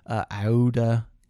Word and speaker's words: Aouda: I looked up the pronunciations of things. So Passepartout, I'm pretty sure, is right. Aouda, A-O-U-D-A, is right, I think.